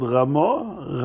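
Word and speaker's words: Ramah (רמ״א): Can you say the word Ramah? Ramah. Ramah stands for three letters. Ramah is spelled Reish Mem Aleph. Reb Moshe Isserles. Reb Moshe, the son of Isser.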